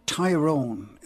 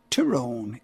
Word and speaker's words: Tyrone: Now, a lot of people say Tyrone. In fact, I do sometimes. But the correct pronunciation is, as Philomena said it there, Tyrone. It's T-Y-R-O-N-E, a village in County Tyrone.